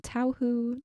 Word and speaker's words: Tauhou: And it is called Tao Hu. That is spelled T-A-U-H-O-U.